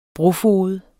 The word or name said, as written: brofoged